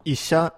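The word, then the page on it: isha: Japanese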